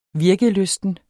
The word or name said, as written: virkelysten